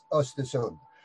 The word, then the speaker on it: Östersund: It's difficult to pronunciate if you're not Swedish, but Östersund.